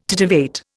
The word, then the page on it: titivate: Indian